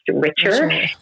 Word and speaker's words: Richer: So that was the birth of Richer Health Consulting, which my last name is actually Richer, but it's phonetically spelled and pronounced Richer.